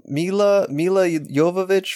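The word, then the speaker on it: Milla Jovovich: Mila Jovovich from Mila Jovovich from Resident Evil, if I'm getting her name correctly.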